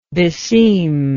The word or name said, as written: beseemingness